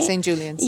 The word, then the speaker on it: St. Julian's: Right now, we are in St. Julian. Am I saying it right? – St. Julian's. – Yeah, at St. Julian's.